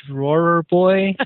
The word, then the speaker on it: Drawer Boy: Michael, it's called The Drawer Boy. Drawer Boy. And Michael got a chance to see it. Drawer, D-R-A-W-E-R, boy.